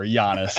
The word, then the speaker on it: Giannis: It's Giannis. There's no jumbo. G. It's not a hard G. – I said Giannis the first time.